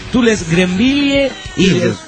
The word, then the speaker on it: Greenville: Pero esa aclara, Greenville, pronunciación. – Ah, Greenville.